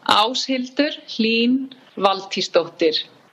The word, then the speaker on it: Áshildur Hlín Valtísdóttir: Quite long and strong and a special name, and it's pronounced in Icelandic, Áshildur Hlín Valtísdóttir.